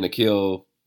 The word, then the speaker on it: Nickeil: how do you pronounce his name? Nick Nick Eel. Yeah, Nickeil.